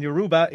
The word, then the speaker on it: Yoruba: Talian is spelled T-A-L-I-A-N. And Yoruba is phonetically Yoruba.